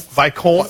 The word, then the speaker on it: Viscount: I think it's Vicon. Am I saying it right? Vicon? – Vicon. Vicon. Viscount Mining Corporation.